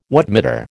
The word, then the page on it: wattmeter: American English